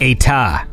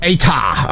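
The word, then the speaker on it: État: I did end up going with, I don't even know how to pronounce this, Etat. I don't know. – Well, it's got an accent aigu at the top, so I think that'd be like Etat, like that. – Etat.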